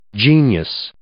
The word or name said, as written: genius